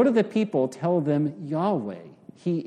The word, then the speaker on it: Yahweh: and most scholars agree that the proper pronunciation is Yahweh.